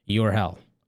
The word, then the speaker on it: Urhell: Vitamin Urhel. You are in hell. That's I'm telling you. They're trolling me. This is spelled U-R-H-E-L-L. Your hell. Urhel. Urhel. Yes, that's what's happening right now with this advent calendar.